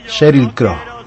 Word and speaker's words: Sheryl Crow: Bueno, y con Sheryl Crow, tengo una pronunciación lamentable, Sheryl Crow, así se dice, lamentable. ¿Usted lo puede pronunciar mejor? – Sheryl Crow. – Ah, ahí me gustó.